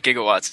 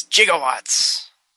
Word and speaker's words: gigawatts: It's not gigawatts. It's gigawatts.